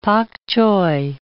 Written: pak choi

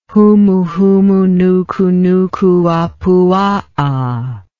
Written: humuhumunukunukuapuaa

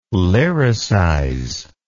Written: lyricize

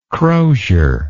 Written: crosier

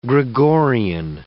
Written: Gregorian

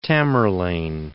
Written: Tamerlane

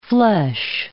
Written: flesh

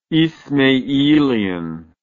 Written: ismailian

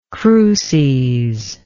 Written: cruces

